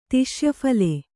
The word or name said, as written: ತಿಷ್ಯಫಲೆ